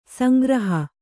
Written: ಸಂಗ್ರಹ

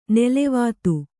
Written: ನಲವತ್ತು

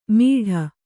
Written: ಮಿಡ

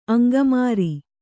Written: ಅಂಗಮಾರಿ

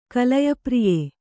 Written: ಕಲಯಪ್ರಿಯ

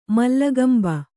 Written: ಮೂಲೆಗಂಬ

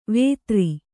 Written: ವೇತ್ರಿ

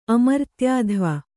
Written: ಅಮರ್ತ್ಯಾಧ್ವ

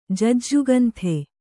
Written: ಜಜ್ಜುಗಂಥೆ

